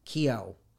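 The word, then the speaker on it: Keough: Is that how you pronounce that? Kehoe? – Kehoe. Okay. I listened to 8 different clips okay. – of All her right. pronunciation so that when I came to do this, I would get it right.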